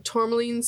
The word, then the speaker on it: tourmaline: It's a tourmaline. – Mm-hmm. Tourmaline. – Yeah, it's like a steroid. – I think it's a gemstone of some sort. Yeah. Pre-workout. – Yeah. Tourmaline sphere aeon stone.